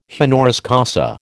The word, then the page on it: honoris causa: American English